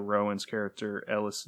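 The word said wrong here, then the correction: Eilis: Lacey, a young Irish immigrant. – Ailish. Yeah, and it's Saoirse.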